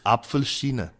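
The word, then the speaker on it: Apfelsine: If I'm pronouncing it, it's Apfelschine. – That's right, Chinese apple.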